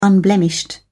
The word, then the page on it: unblemished: British English